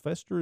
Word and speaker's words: Fester: In the original sitcom of the 1990s, Fester is said to be Morticia's maternal uncle. His name rhymes with Morticia's mother, Hester's.